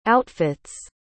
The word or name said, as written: outfit's